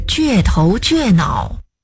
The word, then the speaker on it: 倔头倔脑: 那个字不读绝，而是倔头倔脑。 – 哎，我记得是读作倔强啊。 – 确实读作倔强，指性情刚强而又固执。但是倔头倔脑这个字得读作第四声倔。